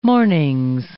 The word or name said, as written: mornings